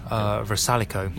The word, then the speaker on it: Vrsaljko: Vrsaljko.